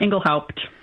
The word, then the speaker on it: Engelhaupt: It's a, you know, it's a German name, and we always pronounced pretty much all the letters in there, Engelhaupt.